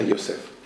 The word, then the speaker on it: Йосеф: Ударение, я, конечно, неправильно поставил, просто так говорят. Правильно, Йосеф. Йосеф, он в Торе назван Бен Пурат Алеаин.